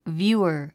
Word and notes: viewer: vjúːə r; ビューアァ

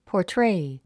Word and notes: portray: pɔːrtréi; ポートレイ

